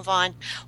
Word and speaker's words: Vaughan: I was in, is it Vaughan? Is that how you say it? – Vaughan, yes, I know Vaughan.